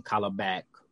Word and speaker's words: Kalibak: So Kalibak, I think that's the name, Kalibak. – It is. You said it right.